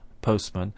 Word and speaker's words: postman: If we take the word postman, for example, the normal way of pronouncing postman is with no T sound in the middle. Of course, we can slow down our pronunciation and say postman if we wish, but normally we just say postman. Similarly, we say Westminster and not Westminster.